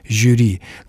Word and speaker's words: жюри: И существуют только такие исключения, как слово «жюри», которое орфоэпические словари, совершенно откровенно, не скрывая это, в угоду традиции, продолжают рекомендовать произносить с мягким «ж». Вот это чуть ли не единственный пример, вот это «жюри», которое нужно произносить именно так.